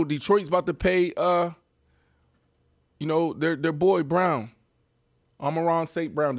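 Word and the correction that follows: Amon-Ra St. Brown: Did I say that right? – Yeah. Amon Ra St. Brown.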